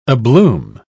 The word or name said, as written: abloom